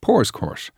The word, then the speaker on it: Powerscourt: Pow's Court. Correct pronunciation is Poor's Court.